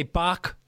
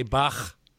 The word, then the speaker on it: Bach: So people say Bach. They don't say Bach.